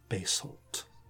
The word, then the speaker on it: basalt: I think this would be bare black basalt or basalt. Never sure which way to pronounce that.